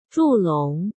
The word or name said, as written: Zhu Long